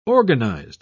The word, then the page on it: organised: British English